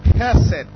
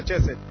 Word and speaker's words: chesed: Naandikwa chesed. Hiyo loving kindness au fadhili. Loving kindness Kiingereza, fadhili Kiswahili, neno chesed inatamkwa C, inaandikwa C H E S E D. Ushaandika? Ukiandika ulikuwa umeandika neno chesed, eh? – Hallelujah.